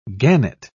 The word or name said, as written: gannet